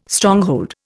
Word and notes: Stronghold: Indian

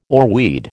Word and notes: oarweed: American English

